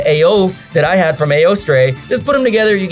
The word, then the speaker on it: Eostre: John thought Esther. I thought Eostre, because again, it's E-O-S-T-R-E. Turns out we're both kind of right. It's Eostre.